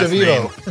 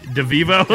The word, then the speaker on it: DeVevo: his last DeVevo. – name. De, DeVevo.